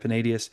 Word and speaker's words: Panaetius: Panaetius, I think is how you say it, Panaetius.